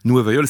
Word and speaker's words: Nueva Yol: Nueva Yol, ça correspond à la façon qu'ont les portoricains de prononcer le nom de la ville de New York.